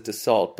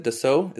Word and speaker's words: Dassault: I've always said Dassault, but I noticed that you said, was it Dassault? Is that how you pronounce it? – Yeah, since it's a French company, that's the way to pronounce it.